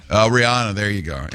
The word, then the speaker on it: Rihanna: And by the way, it's pronounced Rihanna. – Oh, excuse me. – Yeah. Pinky's up. She made it clear. That's the only reason I know. Because everybody says Rihanna.